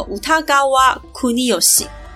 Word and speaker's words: 歌川国芳: 这位绘师他叫做歌川国芳，芳龄几许的芳，日文念作乌塔嘎瓦库尼尤西。